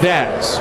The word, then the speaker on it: Vaz: Javi Vaz, who we saw briefly in 2024, has been with the Naturals, rhymes with jazz.